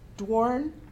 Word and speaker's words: Doiron: His name is Paul Dworn, but it's spelled D-O-I-R-O-N.